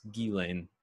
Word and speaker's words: Ghislaine: Ghislaine Maxwell. I mispronounced it in like a couple episodes ago as Ghislaine. It's Ghislaine Maxwell.